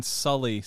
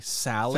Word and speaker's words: Sully: Yeah, so the girl who played Chloe kept calling Sully Sally.